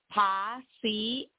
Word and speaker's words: paciencia: The I-E and the I-A at the end of paciencia, that last word, are pronounced separately. So the vowels in Spanish, when they're next uh-huh. – to each other, are pronounced separado, separately, okay?